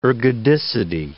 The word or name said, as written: ergodicity